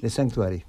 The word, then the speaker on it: The Sanctuary: Lo digo bien, The Sanctuary. – The Sanctuary.